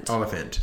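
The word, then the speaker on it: Olyphant: We've got Timothy Oliphant. How do you pronounce his last name? – Oliphant. – Oliphant. Like elephant.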